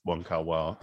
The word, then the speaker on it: Wong Kar-wai: And Christopher Doyle worked a lot with Wong Kar Wai. – Uh, yeah. Wong Kar Wai. – Sorry, Wong Kar-wai. – yeah, said his name wrong.